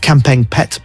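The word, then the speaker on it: Kamphaeng Phet: She's a 25-year-old Thai lady from Kampet province, if I pronounce that right, Kampeng Pet province, although she's actually from Tak.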